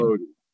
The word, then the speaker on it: Modi: Modi.